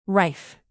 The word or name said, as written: rife